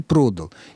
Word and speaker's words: продал: Вот для глагола «продать» возможны в прошедшем времени оба акцентных варианта. Правильным признается всеми авторитетными источниками и ударение на приставке «продал», и ударение на коренном гласном «продал».